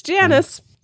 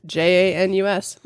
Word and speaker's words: Janus: He didn't change like doing the festivals, but he did change the beginning of the year to January for their god Janus. Is that right? Did I say it right? I feel saying it out loud made me think I said it wrong. Nope, it's Janus. – All right. – Janus, which makes sense for January, right?